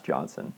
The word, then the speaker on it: Johnson: It's pronounced Hansen. It's German. no, it's pronounced Johnson.